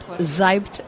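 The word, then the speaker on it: Seibt: At Zeibt Naomi.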